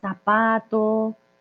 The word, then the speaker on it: zapato: como zapato, pero es como una S. Bueno, si aprendes español de España, ya es otro cuento. Pero si es en Latinoamérica, zapato, zapote, zorro, vergüenza.